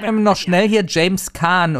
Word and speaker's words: Caan: James Caan oder James Chan oder James... John. – Die erste. – Gut. – Die erste Option, ja.